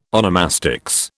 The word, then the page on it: onomastics: British English